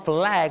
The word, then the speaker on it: flag: ต้องออกเสียงคำว่า flag มี l ชัดเจน